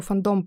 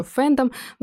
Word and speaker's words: фандом: А, наверное, небольшую нужно сделать такую редакторскую ремарку, потому что само слово «фандом» произносит по-разному, и кого-то бесит произношение «фандом», говорят еще «фэндом», но я вам скажу, слово «фандом» исконно русское.